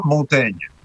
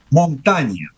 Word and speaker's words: Montaigne: Porque en el francés contemporáneo se pronuncia Montaigne. – Montaigne, sí. – Pero en el francés del siglo XVI, la I, que genera ese sonido en francés de E, una E abierta, cumplía otra función fonética y se pronunciaba montaña. – Uh-huh. – Lo cual quiere decir montaña, sencillamente. Es decir, que si lo fuéramos a pronunciar la vieja usanza detestable, por otra parte, de castellanizar los nombres, podríamos decir Miguel de Montaña.